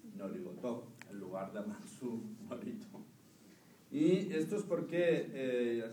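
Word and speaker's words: norito (祝詞): Dice, en el sintoísmo, el término oración se pronuncia norito.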